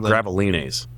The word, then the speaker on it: Gravelines: I think it's Gravelines, because it's the French pronunciation of it.